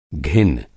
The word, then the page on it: घिन: گھن